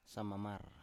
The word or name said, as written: samamar